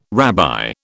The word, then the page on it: rabbi: British English